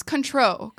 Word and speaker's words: Cointreau: Oh no, I learned how to say it recently. It's control. – Okay. – Control. That's right.